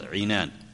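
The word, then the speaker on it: عِنان: ونبهنا أن هكذا تلفظ، عنان بكسر العين وليست العنان بل هي العنان.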